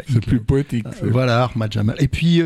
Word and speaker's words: Ahmad Jamal: On va poursuivre donc euh, notre, notre voyage en compagnie euh, d'Armad Jamal on dit Ahmad Jamal. Hein, euh, bon, euh, Abdel est en train de nous fusiller parce qu'évidemment la prononciation est pas pas géniale, mais bon.